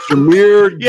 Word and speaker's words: Jahmyr Gibbs: Jahmyr Gibbs.